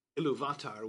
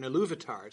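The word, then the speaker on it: Ilúvatar: Tolkien puts the accents in, which are stress marks, so it's actually designed to be a pronunciation aid. So there's an accent on the U in Iluvatar to tell mm-hmm. you that's the stress, because it's an exception to the rule. It's the second instead of the third syllable that's stressed, but yeah.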